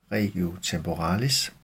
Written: regio temporalis

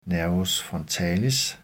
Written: n. frontalis

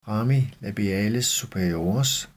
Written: rami labiales superiores

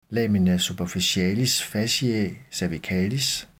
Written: lamina superficialis fasciae cervicalis

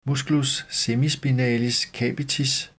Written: m. semispinalis capitis

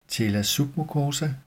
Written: tela submucosa

